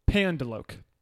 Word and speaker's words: pendeloque: Pendelokes. – Okay, can I get the country of origin? – Yeah. Actually, no, I mispronounced it. Pandeloke.